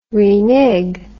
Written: renig

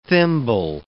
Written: thimble

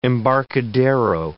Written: embarcadero

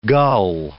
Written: gull

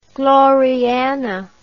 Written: Gloriana

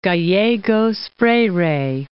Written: Gallegos Freire

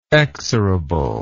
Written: exorability

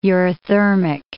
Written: eurythermic

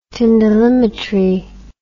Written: tyndallimetry

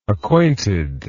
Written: acquaintedness

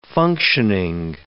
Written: functioning

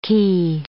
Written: cay

